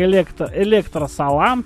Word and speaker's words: Электросалям: что это голос проекта Электросалям. Вот, T-Sex называется трек. Сейчас я открою сопроводиловку, которую Дашка писала сама на себя. Вот. Читается, вот прям смотрите, она вот про себя она написала, читается как электросалам, T-Sex, тираннозавр секс.